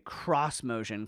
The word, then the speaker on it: X-Motion: Instead of a female voice, Nissan's cross-motion concept, it's spelled X-motion, but screw you, Nissan,